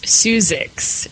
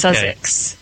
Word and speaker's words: Suzix: And it's actually pronounced Suzix instead of Sussex. Some people say you're at Sussex.